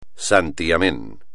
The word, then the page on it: santiamén: espagnol